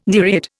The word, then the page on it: nereid: Indian